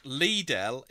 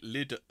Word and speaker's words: Lidl: While it's pronounced Lidl in its own hometown, Germany, most of it actually call it Lidl.